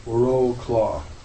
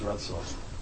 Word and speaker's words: Wrocław: If you see that word, it looks like it's pronounced Wroclaw, but it's actually pronounced Wrocław.